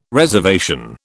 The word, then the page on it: reservation: British English